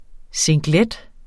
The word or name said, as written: singlet